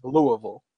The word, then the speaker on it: Louisville: It'll be in Louisville, Kentucky, or Louisville, as I learned when we were there how it's actually pronounced, Louisville.